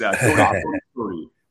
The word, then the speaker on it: Codispoti: It's Codispoti, but people say Codispoti, but it's Codispoti.